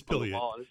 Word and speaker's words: pileated: I have a pileated woodpecker, however you want to pronounce it. – It's pileated. It's pileated.